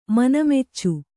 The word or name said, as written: ಮನಮೆಚ್ಚು